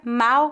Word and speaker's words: mal: A palavra mal com L significa o oposto de bem. A pronúncia dessa palavra é feita com A aberto.